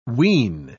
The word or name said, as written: wean